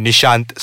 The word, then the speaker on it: Nisshant: Nisshant, spelled backwards.